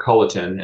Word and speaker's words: Culleton: Well, so my name is Rick Culleton, and that's probably the easiest way to find me is on my own website.